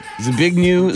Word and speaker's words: Zbigniew: Zbigniew Zarowski. – Zbigniew? See, my wife's Ukrainian, so I think the W is a V, bud.